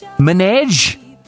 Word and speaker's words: Minaj: Minaj.